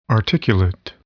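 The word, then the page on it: articulate: adjective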